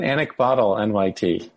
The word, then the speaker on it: anecbotal: It's either anecdotal NYT or anecbotal NYT. How do you pronounce it? – I pronounce it anecbotal NYT.